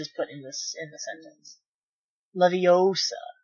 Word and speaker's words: Leviosa: Besides, you're saying it wrong. It's Leviosa, not Leviosa, with, like, the capital A, like, Leviosa.